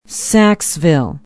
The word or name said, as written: Saxeville